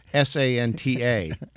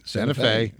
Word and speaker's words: Santa Fe: how do you pronounce Santafe? Because most people will say Santa Fe. Yep. – But no, it is Santa Fe.